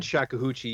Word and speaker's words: shakuhachi: Shakahoochee. Right. I hope I'm saying that right. I should have asked him how to say that. – You said shakuhachi.